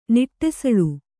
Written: ನಡುಸಾಲೆ

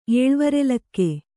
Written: ಏಳ್ವರೆಲಕ್ಕೆ

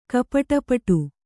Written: ಕಪಟಪಟು

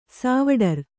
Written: ಸಾವಡರ್

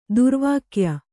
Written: ದುರ್ವಾಕ್ಯ

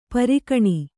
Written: ಪೀರಖಾನಿ

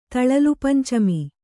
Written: ತಳಲುಪಂಚಮಿ

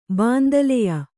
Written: ಬಾಂದಲೆಯ

